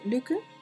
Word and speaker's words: Lykke: Está The Little Book of Luke, que se escribe The Little Book of L-Y-K-K-E, que también es de Mike Viking.